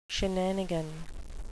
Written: shenanigan

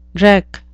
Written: dreck

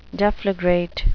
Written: deflagrate

